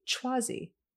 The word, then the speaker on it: Chwazi: Schwazi. C-H-W-A-Z-I.